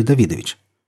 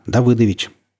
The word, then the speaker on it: Давыдович: А вы случайно не знаете, какое отчество у Кобзона? Давыдович или Давидович?» «Мне это положено знать. Давыдович». «Но ведь он еврей, а еврейское имя Давид пишется и произносится без «ы».